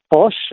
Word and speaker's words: pox: It's actually pronounced Bosch. So sometimes Bosch. The X here is pronounced as an S-H.